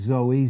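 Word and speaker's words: zoe (ζωή): And eternal life is that Greek word zoe, Z-O-E. It's a long E.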